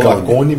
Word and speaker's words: Lacaune: Lacaone? É, Lacaone. Fala Lacone, mas se escreve Lacaune.